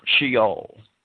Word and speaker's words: Sheol: However, in Hebrew, the name Saul can also be spelled the same as the Hebrew word Sheol, the Hebrew word which we in English pronounce Sheol, which refers to the abode of the spirits of the dead, sometimes simplified as a reference to the grave.